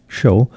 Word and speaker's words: Hsiao: An impressive violin concerto by the Taiwanese composer Tyson Shao. That's spelled H-S-I-A-O, Tyson Shou.